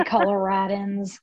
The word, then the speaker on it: Coloradans: We're both Coloradoans. I don't know how you say it. Mm-hmm. You're from Colorado. How do I say it? – They say Coloradans.